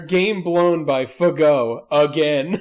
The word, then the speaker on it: Fagot: Last name spelled F-A-G-O-T. – That's exactly right. – And pronounced Fago.